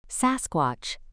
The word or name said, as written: sasquatch